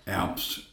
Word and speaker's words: Apps: It's Gerald, J-E-R-O-L-D. Apps, A-P-P-S, and I mostly go by Jerry.